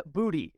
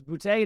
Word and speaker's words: Boutte: Kayshon Booty. It's pronounced Booty, apparently. I thought it was Boutte. It is not. It's Booty.